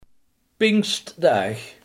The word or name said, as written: pinksterdag